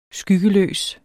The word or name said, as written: skyggeløs